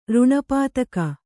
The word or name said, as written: ರುಣಪಾತಕ